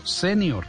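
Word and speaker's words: sénior: se escribe con tilde en la E y se pronuncia así como la estoy diciendo, senior, ah, señor, sí, señor.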